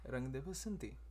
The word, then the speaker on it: Rang De Basanti: This weekend we watched Rang de Basanti. Did I say that correctly? – Yes, Rang de Basanti.